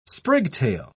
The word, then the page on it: sprigtail: British English